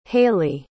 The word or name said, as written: hailee